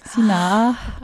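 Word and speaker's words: Sinah: Okay, ja, mich schreibt man natürlich mit H. Mit H am Schluss. Sina.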